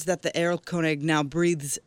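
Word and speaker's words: Erlkönig: It would be Errol Koenig. – Errol Koenig.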